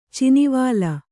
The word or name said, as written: ಚಿನಿವಾಲ್